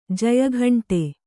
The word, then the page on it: ಜಯಘಂಟೆ: jaya ghaṇṭe